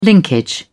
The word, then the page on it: linkage: British English